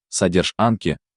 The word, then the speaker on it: Содержанки: Пародия на содержанке, содержанки, ударение на анк, как отдел анкетирования.